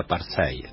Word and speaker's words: apartheid: Tiene una monografía preciosa que se llama La educación en la Sudáfrica de la apartheid. ¿Dije bien? Porque yo inglés no puedo pronunciar ni yes. – Sí, está bien. – Está bien, bueno.